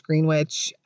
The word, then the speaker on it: Greenwich: I know some of you may be thinking I'm pronouncing Greenwich wrong. However, all of the locals state that it is pronounced Greenwich, and Greenwich, New York is actually the village closer to Manhattan.